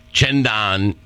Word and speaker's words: cent'anni: Well, Tom, as the Italians say, cendan, you know, that's the southern Italian pronunciation, and 100 years, and also the expression, may you have 100 of these days, Tommy.